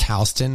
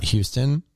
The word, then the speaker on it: Houston: Yeah, it's pronounced Houston, not Houston.